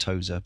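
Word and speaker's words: TOSE: I think it's pronounced Toza, but it's T-O-S-E Co.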